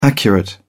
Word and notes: accurate: British English

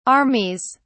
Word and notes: armies: British English

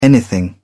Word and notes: anything: British English

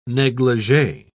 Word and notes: neglige: British English

